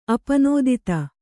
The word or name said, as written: ಅಪನೋದಿತ